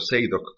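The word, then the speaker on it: Zadok: Eso se llama Sadoc, se lo voy a pronunciar más o menos, Sadoc de Priest. Es algo así como Sadoc el sacerdote, o Seidoc, como dicen ellos, Seidoc de Priest.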